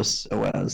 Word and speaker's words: psoas: Oh, and for if you want to do further research, it's called the psoas sign.